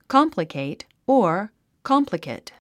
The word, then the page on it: complicate: kάmpləkèit; カンプラケイト